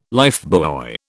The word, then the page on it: lifebuoy: British English